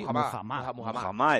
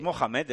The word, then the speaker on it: Muhammad: Muhammad, he dicho. – Muhammad. No había oído nunca esa pronunciación. – Yo tampoco. – No es Mohamed, es que no es Mohamed Ali. Sí que hubo un momento que se le llamaba Mohamed Ali, pero no era.